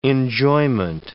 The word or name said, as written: enjoyment